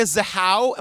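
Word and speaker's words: Zahau: All right, episode one, the case of Rebecca Zahow. Am I saying that right? – You are. Okay. Again, we're going to be saying this a lot. According to the Oxygen documentary, yes, you are saying it correctly.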